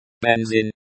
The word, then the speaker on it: Бензин: Бензин. Бензин, say the E as in bed. The Z is pronounced as Z and not like the S in sin.